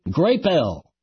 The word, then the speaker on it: Grapple: Let's first probe a new product being promoted under a brand name spelled G-R-A-P-P-L-E. You would naturally pronounce that grapple, as in to wrestle with, but the label instructs us to say grape-l.